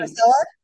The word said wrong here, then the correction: Garcelle: Nope, Garcelle, Garcelle.